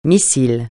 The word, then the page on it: missile: francais